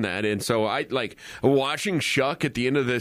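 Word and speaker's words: Tyler Shough: I think it's Shuck. – Shuck. Well, there's no K in there.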